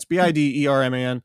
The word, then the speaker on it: Biderman: It's at Chris Biederman. My last name is spelled Biederman, which I does not make a long E sound in most of English.